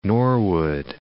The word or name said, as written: Norwood